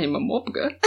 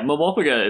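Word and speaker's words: MMORPG: Is that the accepted pronunciation of that? – Yeah. – Okay, just... – Oh, really? – Yeah. Momopaga is, like, a real thing that people say.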